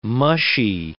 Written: mushy

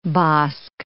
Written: Bosc

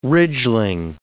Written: ridgeling

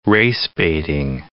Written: race-baiting

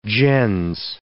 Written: gens

